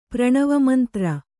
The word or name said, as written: ಪ್ರಣವಮಂತ್ರ